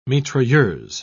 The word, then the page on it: mitrailleuse: British English